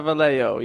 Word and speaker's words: Vallejo: And then in August 1st of 1969, three letters prepared by the killer were received at the Vallejo... I'm probably saying that wrong. I'm really sorry. – It's pronounced Akren. – Did you say Vallejo? – It's a Vallejo, yeah.